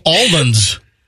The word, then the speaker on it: almond: See, I don't pronounce the L in almond. It's almond. Almond.